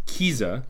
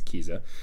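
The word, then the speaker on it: Kiesza: So this track, it's important to mention, features, I believe it's pronounced Kiza, K-I-E-S-Z-A. I believe it's Kiza, who is a pop singer who's not far into this kind of music.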